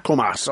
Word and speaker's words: Comas: Comas, okay.